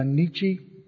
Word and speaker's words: Nietzsche: Here's a quote from Nietzsche.